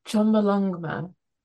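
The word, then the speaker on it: Chomolungma: So, Chommalongma.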